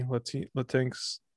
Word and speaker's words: Latinx: I'd also like to apologize to the Latinx community, Latinx, for my unaired comments. – I think that's how it should be. That's how it should be pronounced.